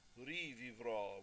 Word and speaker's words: rivivrò: Rivivrò with the open O and the accent on the end, and that takes you through the end of the piece.